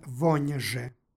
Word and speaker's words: vonje: stihu pročitali smo vonje sa jotovanim n u ovoj složenici. Dakle, vonje.